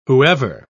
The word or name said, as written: whoever